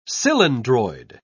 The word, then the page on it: cylindroid: English; British English